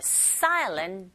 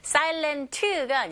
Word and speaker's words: silent: Wakes the silent desert. 보통 N 사운드와 T 사운드가 같이 붙어 있는 경우에 T 사운드가 잘 안 들리거든요. 네, 북미권 영어 발음의 특징이기도 하죠. Silent가 아니라 silent. Silent. 거의 안 들리죠?